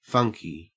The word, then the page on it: funky: Australian English